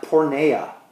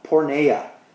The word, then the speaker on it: porneia: This word in the Greek means, or is pronounced porneia, porneia. So to flee sexual immorality, the Greek word porneia, means to have sex with someone that you're not married to.